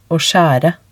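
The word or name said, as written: å skjære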